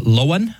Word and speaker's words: Loan: It was Loan, I believe is how you would pronounce it, L-O-A-N. It's spelled like loan, like you would get something loaned to you.